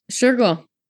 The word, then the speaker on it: Shergill: Shergill.